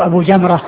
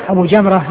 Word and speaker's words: أبو جمرة: وأبو حمزة وأبو جمرة بينهم شيء من الاشتباه, يعني من ناحية يعني من قبيل المؤتلف والمختلف, يعني تتفق الألفاظ يعني في الرسم وتختلف في النطق, لأن يعني الرسم واحد, أبو حمزة وأبو جمرة قسمها واحد, لو ما في نقط انسبس هذا بهذا لو ما في نقط, والفرق إنما هو بالنقط.